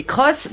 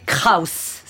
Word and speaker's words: Kraus: Non, c'est Cross prononcé Kraus, c'est-à-dire Chris Cross, Chris Kraus est allemand.